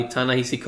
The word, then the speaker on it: Ta-Nehisi: Black Panther number 1, written by Ta-Nehisi Coates, and I will never be sure if I'm pronouncing it right.